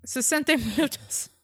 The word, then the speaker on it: Sesenta Minutos: Sesenta Minutos.